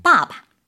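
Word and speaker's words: 빠바 (爸爸): 빠바. – 빠바. – 근데 아빠를 빠빠 하면 너무 웃길 거 아니에요. – 네. – 뒤는 약하게 발음해 주는 거죠.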